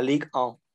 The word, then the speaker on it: Ligue 1: Ligue 1, si lo dije bien, tú que hablas francés. – La, Ligue 1, correcto.